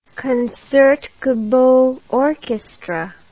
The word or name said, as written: Concertgebouw Orchestra